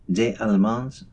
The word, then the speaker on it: Des Allemands: Katie Hopgood Ray along with Day Ray, Des Allemands from the new album I Dream of Water.